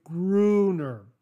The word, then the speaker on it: Gruner: Mostly first vowel is the long U. We are taught that generally vowel plus consonant plus letter E makes the vowel long. So you'd say Gruner.